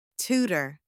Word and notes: tutor: anglais

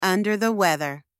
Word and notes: under the weather: anglais

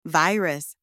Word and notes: virus: anglais

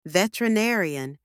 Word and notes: veterinarian: anglais

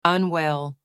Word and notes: unwell: anglais

